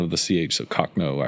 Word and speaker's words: Cochno: Cochno. Yeah, Cochno stone.